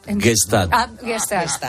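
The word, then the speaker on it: Gstaad: Se dice así, ¿no? ¿Tú qué has ido? Gostad. – ¿Cómo? – La estación de esquí Gostad. – Gestad.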